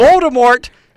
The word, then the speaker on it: Voldemort: Voldemort, you pronounce the T.